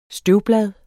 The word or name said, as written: støvblad